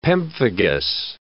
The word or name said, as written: pemphigus